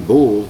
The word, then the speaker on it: Bul: And that'll be Bul, that's spelled B-O-O-L. In English they spell it B-U-L, which is a little different.